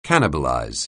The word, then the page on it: cannibalize: anglais